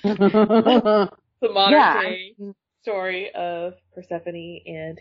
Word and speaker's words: Persephone: And Persephone is the correct pronunciation.